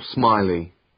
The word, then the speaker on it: Smiley: Uh, Paul Smiley.